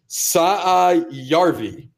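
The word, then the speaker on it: Saarijärvi: Sarivi, and it's Saayarvi.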